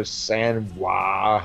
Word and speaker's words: Samhain: But in between, there was Samhain, or Sanwah, however the fuck Danzig wants you to pronounce it.